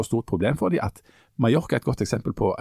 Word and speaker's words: Mallorca: Eh, Mallorca, da, som uttales Mallorca eh, Men eh, det, de, det, var jo en sånn masseturismeplass.